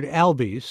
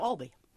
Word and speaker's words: Albee: In Pulitzer Prize-winning playwright Edward Albee's Seascape, and I pronounce it that way because I've said Albee through the years even when I interviewed him, but... – No, Albee. – let's say it right. Albee.